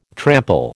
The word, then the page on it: trample: American English